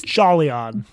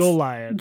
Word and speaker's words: Jolyon: His name is spelled J O L Y O N. It looks to me like it should be pronounced Jolion. Jolion. It is actually pronounced Jolion. Jolion. Like Julian, but with a Joe.